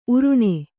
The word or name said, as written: ಉರಣ